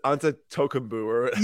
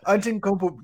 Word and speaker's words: Antetokounmpo: It's Antetokounmpo. – Yeah. Antetokounmpo.